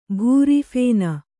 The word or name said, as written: ಭೂರಿಫೇನ